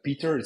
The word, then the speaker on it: Pieter: You can send me an email, Peter. It's P-I-E-T-E-R. It's like Peter in English, but then with the I before the E. That's how we pronounce it in Dutch.